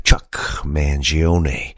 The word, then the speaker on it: Chuck Mangione: And he said, ladies and gentlemen, please welcome Chuck Mangione. So I know I pronounce his name correctly because I heard it come right out of his mouth.